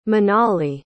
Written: minali

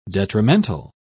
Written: detrimental

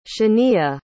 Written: shaniya

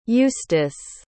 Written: justis

